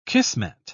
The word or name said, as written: kismet